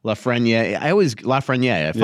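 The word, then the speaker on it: Lafrenière: Preston asks, could Lafreniere, finally pronounced it correctly.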